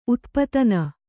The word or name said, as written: ಉತ್ಪಾದನೆ